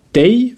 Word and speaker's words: dig: Dei wird D-I-G geschrieben, aber eben D-I-J, also dei ausgesprochen.